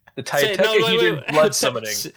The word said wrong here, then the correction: Tridecahedron: Say it right. Tidecahedron. – Tridecahedron blood summoning.